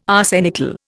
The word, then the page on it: arsenical: Indian